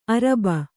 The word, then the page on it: ಅರಬ್ಬಿ: Kannada